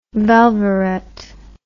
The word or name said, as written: velveret